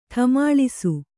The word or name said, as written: ಠಮಾಳಿಸು